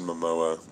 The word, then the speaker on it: Momoa: My thing was, and I think that with Jason Momoa, which is, by the way, how you pronounce his name,